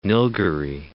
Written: Nilgiri Hills

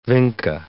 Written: vinca

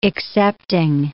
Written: excepting

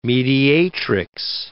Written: mediatrix

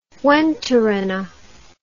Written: Juantorena

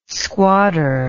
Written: squadder

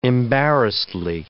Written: embarrassedly

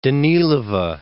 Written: Danilova

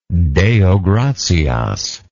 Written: deo gratias

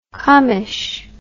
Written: commish

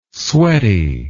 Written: sweaty

